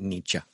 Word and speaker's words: Nietzsche: And there have been times I picked up Nietzsche or somebody like that, or to say it correctly, Nietzsche.